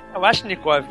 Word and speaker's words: Kalashnikov: Até onde eu sei é Kalashnikov. – Automático, como é que... O V é U? – Não. – V é U. – Claro que não.